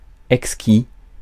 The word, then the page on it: exquis: France; [ɛk.ski]